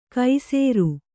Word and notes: ಕೈಸೇರು: kai sēru

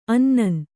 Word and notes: ಅನ್ನನ್: annan

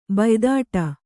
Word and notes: ಬಾಯಿತೀಟೆ: Kannada; bāyi tīṭe

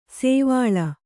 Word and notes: ಸೋವಳಿ: kannada

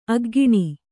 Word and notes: ಅಗ್ಗಿಣಿ: aggiṇi

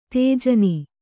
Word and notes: ತೇಜನಿ: tējani

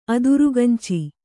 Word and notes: ಅದಿರ್ಗಂಚಿ: adirgañci

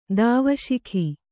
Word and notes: ಧೀವಸಿಗ: dhīvasiga

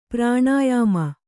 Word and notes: ಪ್ರಾಣಾಯಾಮ: kannada

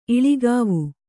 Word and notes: ಇಲಿಗಿವಿ: iligivi

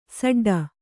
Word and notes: ಸಡ್ಡು: saḍḍu

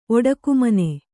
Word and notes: ಒಡಕುಮನೆ: oḍakumane

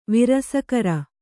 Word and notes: ವಿರಸಕರ: virasakara

